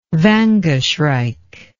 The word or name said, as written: vanga shrike